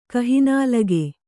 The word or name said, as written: ಕಹಿನಾಲಗೆ